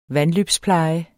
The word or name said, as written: vandløbspleje